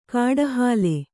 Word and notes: ಕಾಡುಹುಲ್ಲು: kāḍu hullu

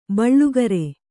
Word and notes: ಬೊಳ್ಳುಗರೆ: boḷḷugare

